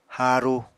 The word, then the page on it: haaru: Apatani